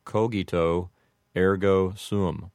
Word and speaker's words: cogito ergo sum: If you wanted to apply the rules of Latin pronunciation, you would make the G in cogito a hard G. That means that it would have a G sound. And the O's would have a long O sound. So it would sound something like cogito ergo sum.